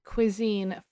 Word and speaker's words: cuisine: It has a pronunciation. It is cuisine from French. Cuisine.